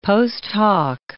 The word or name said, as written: post hoc